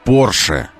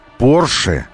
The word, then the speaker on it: Porsche: Porsche. Porsche. И немецкие друзья наши говорят Porsche. И британские друзья наши говорят Porsche в произношении. И мы давайте условимся с вами говорить Porsche. Вот так это произносится.